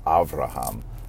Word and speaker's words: Avraham: For example, even the name Abraham, sometimes in some languages pronounced Avraham. That B and that V slip around with one another.